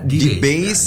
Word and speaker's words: D-Base: Jadi bacanya yang benar adalah D-Base ya, project ya.